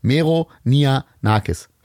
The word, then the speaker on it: Meronianakis: Es, ist, es sind drei Silben. Meronianakis.